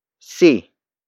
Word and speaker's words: si: See. See. See is spelled as S-I.